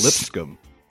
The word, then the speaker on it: Lipscomb: Lipscomb.